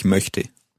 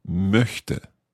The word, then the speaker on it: möchte: The word would be ich möchte. – Ich möchte. That's quite a tricky vowel sound to make there.